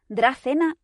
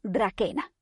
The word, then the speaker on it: Dracaena: Y es que, quizás era incorrecto, no he logrado aclararlo del todo, pero me niego a pronunciar el nombre científico de los árboles dragón originarios como Dracaena o Dracaena, como hacen los ingleses. O sea, no. Su nombre se escribe Dracaena. Y o lo voy a pronunciar así o a malas, Dracaena. Porque la raíz etimológica de su nombre está en la palabra griega dracón. Y no voy a quitarle esa K tan dracónica y condenarla al ceceo.